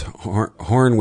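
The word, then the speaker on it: Horne: It's Horn with an E. The E is silent, as he jokingly says.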